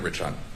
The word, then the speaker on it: Rich Hunt: Lieutenant Rich Hunt. – Can you spell it? – R-I-C-H-H-U-N-T.